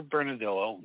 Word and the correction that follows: Bernalillo: You mean Bernalillo. That's how you pronounce Bernalillo.